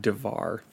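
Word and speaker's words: Devar (דבר): devar. And this word D A V A R, it's pronounced Devar like Lamar, like like Lamar Odom used to be on the Lakers, which is funny because I remember two or three years ago, I was right before COVID.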